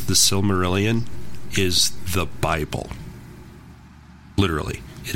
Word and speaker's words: Silmarillion: I read somewhere recently that they are trying to get the rights from Tolkien's estate to make, I always butcher it, Silmarillion. – That's it. – Silmarillion. – Yep. – Yeah. – That's it. – It's the middle L messes me up.